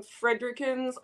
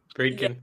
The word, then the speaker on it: Friedkin: But I had a similar yet different experience to William Fredrickson's, I never pronounce his last name, Cruising, which is another problematic film. – Yes. Friedkin's, I think. – Friedkin.